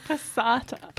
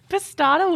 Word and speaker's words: Passata: How do you say it? – Passata. – Passata. Passata. – Passata works too.